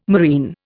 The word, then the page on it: marine: Indian